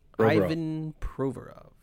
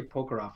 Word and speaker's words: Provorov: How do you say the guy's name again, the Flyers guy? – Ivan Provorov. – Provorov. I don't know why I want to say Provorov for some reason, but it's Provorov.